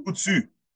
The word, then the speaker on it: Coutu: I'm saying that right? – Couchou? Yes, you're getting better and better. – Sam Couchou. Sam Couchou. – Couchou.